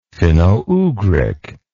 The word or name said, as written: finno ugric